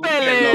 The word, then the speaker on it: Pelé: Aquí es Pelé, el rey del balón. – Aquí lo pronuncio como el brasileño.